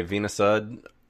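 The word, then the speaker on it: Veena Sud: It is directed by Veena Sood, I think is actually how you pronounce it.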